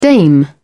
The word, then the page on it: dame: British English